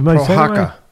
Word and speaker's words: Prochazka: Prochazka, how do you pronounce it? – Prochazka. – Prochazka? – Yeah.